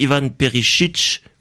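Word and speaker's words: Ivan Perišić: On a fait venir tout récemment donc Ivan Perisic. puisque c'est comme ça qu'il faut prononcer. Il l'a lui-même confirmé en conférence de presse.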